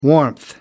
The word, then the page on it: warmth: American English